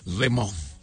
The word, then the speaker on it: Le Mans: Bueno, voy a decirlo en castellano, Le Mans, pero ¿cómo es la pronunciación? – Le Mans. – Le Mans. Ok.